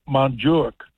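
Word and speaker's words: Montjuïc: And they have Montjuic, they call it today, is how they pronounce it in that funky language.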